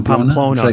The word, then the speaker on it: Pamplona: Pamplona, was that the city? – Yeah, Pamplona, I think. – Oh, Pamplona. – It's like south. – Yeah, I said it wrong.